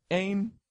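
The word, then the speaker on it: Ein: His number 2, I don't know how to say this, it's E-I-N from Cowboy Bebop. Is that Ayn? – Yeah.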